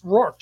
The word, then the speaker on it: Rourke: So phonetically, it's Rourke, just like you're showing it on the screen.